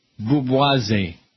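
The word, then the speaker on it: booboisie: It's spelled B-O-O-B-O-I-S-I-E-S. Boubouise. – Okay. This is a made-up word? – No. It's a blend of boob and bourgeoisie. – Okay. Boubouise.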